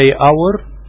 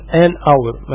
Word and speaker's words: hour: وكمان الآن بتستخدم قبل الحرف H إذا جه في أول الكلمة وما بيتنطقش، زي hour ساعة، احنا قلنا ما بننطقش ال H فبنقول an hour، ما بنقولش a hour بنقول an hour.